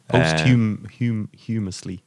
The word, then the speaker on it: posthumously: Is that how you pronounce it? – Yeah. – Posthumously. – Posthumously. I